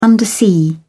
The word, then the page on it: undersea: British English